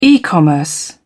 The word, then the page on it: e commerce: British English